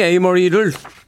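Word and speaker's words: Amerie: 제가 한 번 이 에이머리를 반사적으로 아멜이라고 읽었던 적이 있는데 예, 우리 민용 PD가 걱정이 좀 됐던 모양이에요. 음악 나가는 동안 에이머리야라고 툭 한마디 해줬습니다.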